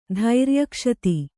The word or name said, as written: ಧೈರ್ಯಕ್ಷತಿ